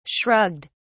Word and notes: shrugged: British English

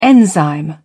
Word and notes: enzyme: British English

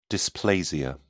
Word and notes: dysplasia: British English

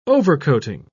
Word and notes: overcoating: British English

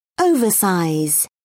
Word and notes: oversize: British English

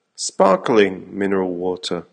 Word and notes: sparkling mineral water: American English

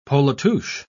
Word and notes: polatouche: British English

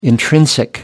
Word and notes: intrinsic: American English

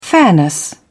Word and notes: fairness: British English

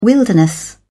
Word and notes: wilderness: British English

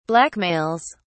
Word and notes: blackmails: British English

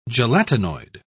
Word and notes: gelatinoid: British English

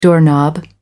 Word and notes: doorknob: American English

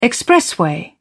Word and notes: expressway: British English

